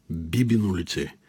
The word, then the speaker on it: Бибино лице: Бих искал да отбележа още, че за да можем да го произнасяме правилно, ние трябва да поставяме ударението на първата сричка – „Бибино лице“.